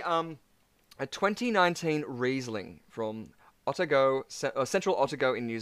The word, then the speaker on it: Otago: It's pronounced Otago.